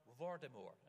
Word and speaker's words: Voldemort: Voldemort. And that's the way J.K. Rowling says it should be pronounced. No T at the end.